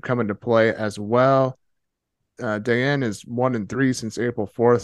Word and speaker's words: Dellien: It currently is against Hugo Dayen. Is that how you say it? – Dayen, yeah.